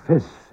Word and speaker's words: fys: And the word fis, F-Y-S, means knowledge.